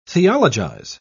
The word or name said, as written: theologize